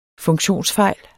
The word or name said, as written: funktionsfejl